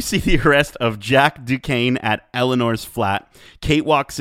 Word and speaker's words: Duquesne: Then we see the arrest of Jack Duquesne. Is that how you pronounce that? Duquesne? – Duquesne? – It's just Duquesne? Okay. Jack Duquesne, is the SNE, is that like a silent thing?